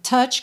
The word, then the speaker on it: touch: Atenção à pronúncia dessa palavra. Touch, que se escreve T-O-U-C-H, mas é touch. Touch me, tá? Tem que ter esse tch antes de mudar para o me.